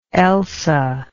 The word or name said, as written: elsa